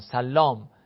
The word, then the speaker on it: سلام: حالا من درست تلفظ کنم، سلام ابن سلیمان، سلام درستش هست، ثقتون، این سلام ابن سلیمان فرد ثقه هست، مورد وثوق هست، مورد اطمینان هست.